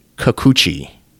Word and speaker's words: Kikuchi: Tsuyoshi Kikuchi. K-I-K-U-C-H-I.